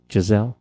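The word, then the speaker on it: Gisele: Giselle.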